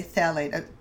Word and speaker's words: phthalate: I should say phthalate, I pardon for incorrectly pronouncing it.